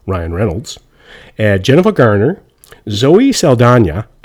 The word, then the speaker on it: Saldana: And so Zoe's name tricks me up because she's got an accent on the N, but she doesn't have an accent on the E. So it should really be Zoe Saldana.